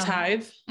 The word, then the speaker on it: tithe: So then comes the tithy, and the tithy is kind of like taxes. And oh, like a tithe. – Mm-hmm. Tithe, is that how you say it? – T-I-T-H-E. – Mm-hmm. Yep. Tithe.